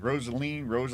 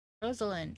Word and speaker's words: Rosaline: She is the enchanted Rosaline to my not-so-Romeo. – Wow. – I know. How do you pronounce it? – Rosaline. – Okay, Rosaline.